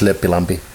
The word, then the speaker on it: Lempilampi: You took me to my childhood just now because people used to call me Lempilampi, which is like Lempi. It's like the kind of love and whatever. Yeah. But actually, it's Lempilampi.